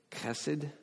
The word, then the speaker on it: Chesed: In fact, this word that's so common in Scripture to describe God, it's a word that if you said it right in Hebrew, it'd sound like you're coughing almost. Chesed, okay? C-H-E-S-E-D.